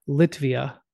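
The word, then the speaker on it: Lietuva: I believe the way the Lithuanians pronounce it is Litvia.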